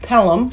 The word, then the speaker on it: Pelham: Was a John Travolta Taking of Phelim 123? – Pelham? – Oh, yeah. – Yes, it's pronounced Pelham.